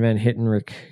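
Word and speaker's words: Van Hentenryck: Even Kevin Van Hittenrick, that's how you pronounce his name.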